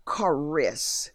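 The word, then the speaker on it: charis (χάρις): In the Greek, this word grace is pronounced as charis, meaning a gratifying manner or act of God and his divine influence upon the heart.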